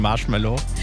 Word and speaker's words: Marshmello: Jetzt Kiss My Friends von Marshmello.